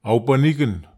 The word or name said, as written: aupaniken